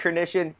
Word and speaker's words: Hrynyshyn: Mike Hrynyshyn. Yes, if you follow me on Twitter, that is actually how you say that last name.